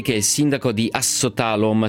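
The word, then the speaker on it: Ásotthalom: La pronuncia giusta è Assotalom, ecco. È una S strisciata, però va bene.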